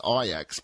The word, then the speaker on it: Ajax: There was the Dutch football team, mind you, pronounced Ajax,